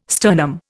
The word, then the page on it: sternum: Indian